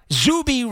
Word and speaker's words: Zuby: But Zuby rhymes with Loogie.